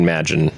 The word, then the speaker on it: Magin: It's like imagine, but it's Ryan Magin.